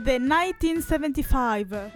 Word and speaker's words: The 1975: Volevo parlare del 1975, che ovviamente si pronuncia in inglese, ma non fatemelo dire. – Sarebbe The 1975.